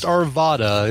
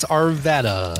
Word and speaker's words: Arvada: To be pronounced Arvada. It's Arvada. – Ah, like Nevada, Nevada. It's the same thing, right?